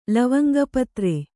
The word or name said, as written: ಲವಂಗಪತ್ರೆ